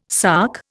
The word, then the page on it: sark: Indian